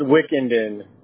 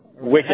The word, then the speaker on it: Wickinden: It's Wickenden. Wickenden. – How do you spell that? W-I-C-K. I-N-D-E-N.